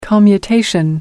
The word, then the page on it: commutation: American English